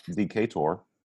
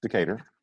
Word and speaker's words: Decatur: That I know how to pronounce. It's not Decatur. It's Decatur.